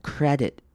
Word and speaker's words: credit: 好，接下来看到 credit 这个字，这个字要注意的是它的 i 后面的 i 要念成短母音 i 的声音，这个字也是大家很常念错的 credit。